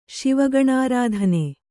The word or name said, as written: ಶಿವಗಣಾರಾಧನೆ